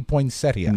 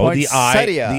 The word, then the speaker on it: poinsettia: It's spelled P-O-I-N. – Sleeping 2 hours at a time. What? – P-O-I-N-S-E-T-T-I-A, so it would be poinsettia. – No, poinsettia. The I is silent.